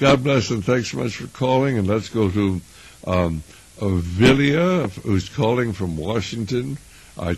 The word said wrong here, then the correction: Celia: Celia, C-E-L-I-A. Okay, Celia. Well, I got V-I-L-I. Celia, C-E-L-I.